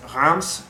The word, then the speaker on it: Reims: It says Reims, R-E-I-M-S, pronounced kind of like Rince, but with an M.